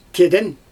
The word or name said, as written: keden